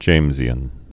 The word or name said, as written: Jamesian